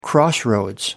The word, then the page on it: crossroads: American English